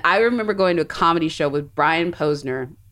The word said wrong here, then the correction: Posehn: which Mark, you probably know. – Posehn? – Posehn. I'm getting his name wrong.